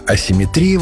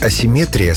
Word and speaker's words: асимметрия: А асимметрия вошла позже, уже как термин научного языка, ближе к греческому оригиналу, где ударение было иным. Вариант асимметрия словарями не поддерживается и считается ошибочным. Так что в русском языке симметрия есть, а вот асимметрии нет.